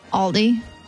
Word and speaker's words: Aldi: Aldi. The grocery store? – Mm-hmm. Aldi.